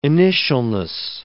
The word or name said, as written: initialness